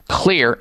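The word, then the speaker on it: Xlear: And the one that I'm focusing on now, especially this time of the year, is the one called Clear. And by the way, folks, Clear is spelled with an X. X-L-E-A-R.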